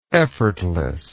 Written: effortlessness

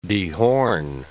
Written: dehorn

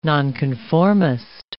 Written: nonconformist

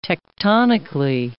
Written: tectonically

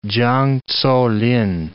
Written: Chang Tso-lin

